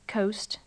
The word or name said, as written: coast